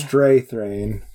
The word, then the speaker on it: Strathairn: Oh, I can never remember how to say his name. – Strathairn? – Strathairn. – Right. It's close enough.